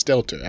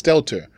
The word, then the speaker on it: Stelter: So, let me, I want to, so yesterday was Stelter. – His last day. – It's Stelter. I don't know why I pronounce it with a Z, but it's Stelter.